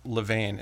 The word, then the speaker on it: Levain: Also wanted to thank you for pronouncing it Levain in the traditional patriotic, incorrect French pronunciation.